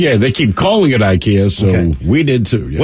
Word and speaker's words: IKEA: Subject is IKEA. IKEA made the announcement probably,